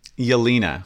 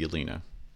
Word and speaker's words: Jelena: Yelena. It's spelled Jelena, but I guess it's pronounced Yelena.